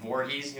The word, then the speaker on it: Voorhees: Um, he's originally from Voorhees, New Jersey. Is that how you pronounce it? Yeah. Yeah.